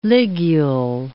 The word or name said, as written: ligule